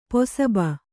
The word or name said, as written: ಪಸುಬೆ